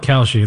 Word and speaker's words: Kalshi: Is it Kalshi? – I call it Kalshi.